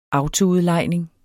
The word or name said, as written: autoudlejning